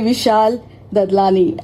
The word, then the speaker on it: Vishal Dadlani: And this is one and only Vishal Dadlani.